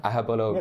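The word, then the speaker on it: Aghabullogue: And Agabalogue, am I pronouncing that right? – Ahabalogue. – That's the one.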